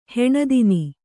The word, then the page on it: ಹೀನತನ: hīna tana